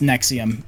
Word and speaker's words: NXIVM: Yeah, but it was pronounced Nexium, but it was just Roman numerals.